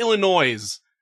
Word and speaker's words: Illinois: Do you say, I call it Illinois. I've been told that's wrong, but there's a fucking S in there.